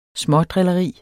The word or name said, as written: smådrilleri